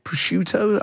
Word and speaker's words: prosciutto: Says, asparagus wrapped in prosciutto. Am I saying that right? – Yeah, yeah.